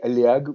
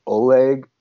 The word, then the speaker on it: Oleg: Well, the truth is that my name is actually Oleg, which is a Russian name. – Okay. – But when I immigrated here as a little kid, no one could pronounce it. Everyone pronounced it as Oleg, and I just hated that. So that's why I changed it as a little kid, because I just didn't want to hear my name mispronounced the whole time.